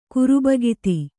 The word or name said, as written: ಕುರುಬಗಿತ್ತಿ